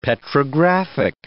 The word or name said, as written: petrographic